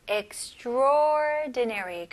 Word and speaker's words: extraordinary: 하지만 발음할 때는 extraordinary. 그래서 A를 그냥 아예 무시를 하시는 게 좋아요. extra 보다는 그냥 바로 O로 넘어가서 extraordinary.